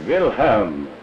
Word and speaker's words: Wilhelm: A-L-B-E-R-T. And Wilhelm, W-I-L-H-E-L-M, Wilhelm. And Trana, T-R-A-N-A.